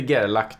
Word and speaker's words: Gerlach: Men, men nu är, det, är jag väldigt osäker så jag kommer säga Peter Gerlach. Ja, Peter Gerlach, det var så det var.